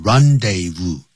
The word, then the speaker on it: rendezvous: The Moulin Rouge was the rendezvous, 啊，就念成 rendezvous, du high life.